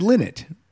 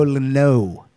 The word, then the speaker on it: Lynott: Now, did we ever get a distinction on the right pronunciation of his name? – I mean, Ricky Warwick said Lynott, right? – Yeah.